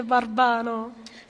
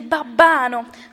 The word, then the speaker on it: babbano: A non si dice barbano. Si dice babbano.